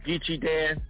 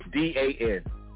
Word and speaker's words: Geechie Dan: I'm heavy on Facebook at Geechie Dan. My name is spelled GEECHIEDAN, Geechie Dan.